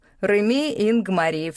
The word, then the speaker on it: Реми Ингмари: Меня зовут Реми Ингмари, все с ударением на последний слог.